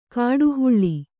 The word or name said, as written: ಕಾಡ್ಹಳ್ಳಿ